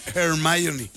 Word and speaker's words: Hermione: Ya la ando rec- Hermione se escribe con H, Hermione, pero se pronuncia Hermione.